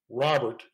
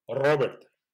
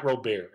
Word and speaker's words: Robert: So, well, sky's the limit for Luis Robert, and that is how he says it, he pronounces it Robert, even though I think if he's completely honest, you roll that R, sounds a little bit different, more like Robert. You know, but it's just easier to pronounce it out. – My point being, it's not Robert. Everybody for some reason seems to want to say Robert, but that's wrong.